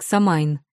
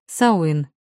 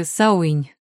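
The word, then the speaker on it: Samhain: отмечать американский Хэллоуин как языческий праздник Самайн. В русском языке закрепилось именно такое прочтение, но правильно говорить «сауин», даже «сауинь» с мягким «н» на конце.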